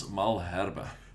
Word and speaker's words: Malherbe: At hooker, he's gone with Bongi Banambi and Franz Malerby. Tell me what I butchered. – All of it. Tendai Tabariria. Oh my goodness, I can't say it either now. Bongi Mbanambi and Franz Malherbe.